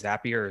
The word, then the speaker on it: Zapier: If everybody doesn't know if it's Zapier or Zapier, Zapier rhymes with happier is what they said.